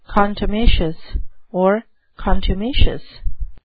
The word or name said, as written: contumacious